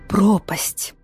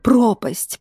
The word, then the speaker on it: пропасть: Пропасть, пропасть, пропасть. Переставить ударение, и получится глагол с тем же смыслом.